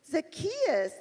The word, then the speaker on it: Zacchaeus: Is that guy, his name's got Zacchaeus, three syllables? Zacchaeus, that's right.